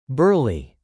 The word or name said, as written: burly